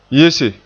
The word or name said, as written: yese